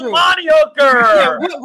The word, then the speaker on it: Amari Hooker: Yeah, I mean, off the top of my head, everyone's saying Amari Hooker, Amari Hooker, and they can't even say his name right. It's Amari Hooker.